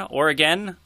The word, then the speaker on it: Oregon: Wait, Oregon? Oregon? – It's Oregon. – Oregon. Okay. Yeah, I think I believe that's in the script actually, as someone gets corrected on their pronunciation. – Yeah. Yeah. Oregon. Oregon is going to win. Okay. Yeah, gin with win.